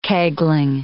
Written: kegling

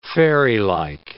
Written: fairylike

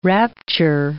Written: rapture

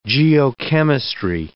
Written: geochemistry

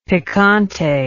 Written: picante

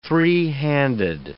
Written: three-handed

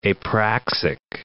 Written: apraxic

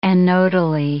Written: anodally

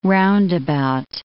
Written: roundabout

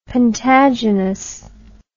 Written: pentagynous

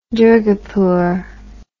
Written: durgapur

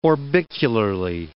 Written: orbicularly

